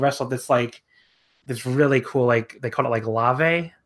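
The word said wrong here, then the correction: Llave: or I might even be pronouncing that wrong. – Is it Yave? – Yave. – Is it LL? Yeah.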